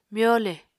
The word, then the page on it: myole: Apatani